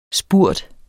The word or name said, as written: spurt